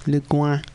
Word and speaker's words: Le Guin: That's L-E-G-U-I-N. I was just putting a little French twist on it. Because it's a French name, Le Guin. Or Le Guin, if you must pronounce the N.